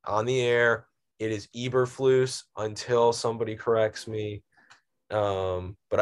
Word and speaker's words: Eberflus: First thing, most important, I know now that it is pronounced Matt Eberflus, not Eberflus.